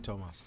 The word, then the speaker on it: Tomas: Spelled without the H, but I didn't. – It's usually Tomas.